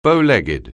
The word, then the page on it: bow-legged: anglais